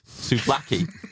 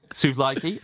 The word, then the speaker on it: souvlaki: And there was a place called Souvlaki that did souvlaki. – but you like it. – But you like it. – Yeah. – So he said souvlaki, and I went, it's pronounced souvlaki.